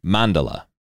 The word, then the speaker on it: Mandala: The first sentence of the Wikipedia description for Mandala says, emphasis on first syllable. So it actually should be Mandala.